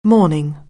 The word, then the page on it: morning: anglais